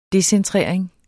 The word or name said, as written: decentrering